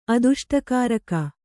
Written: ಅದುಷ್ಟಕಾರಕ

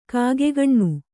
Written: ಕಿಗ್ಗಣ್ಣು

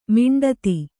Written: ಮಂಡಿತ

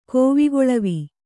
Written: ಕೋವಿಗೊಳವಿ

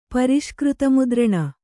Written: ಪರಿಷ್ಕೃತಮುದ್ರಣ

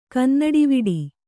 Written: ಕನ್ನಡಿವಿಡಿ

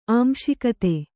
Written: ಆಂಶಿಕತೆ

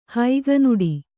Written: ಹಗಣಾಟ

